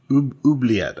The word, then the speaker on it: oubliette: I think you're pronouncing that word wrong. Can you say it again? – Obelette. – Oubliette, I think, is the vocab.